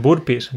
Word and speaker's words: burpees: Burpees, creo que es uno, pero no sé cómo se pronuncia. Sí, decimos burpees, decimos, sí.